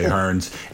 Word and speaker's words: Hurns: I actually agree with your assessment of Earns as well, although I don't agree with how you pronounce his name because it's pretty clearly Hurns. – Unless he's in London, you should be calling him Hurns.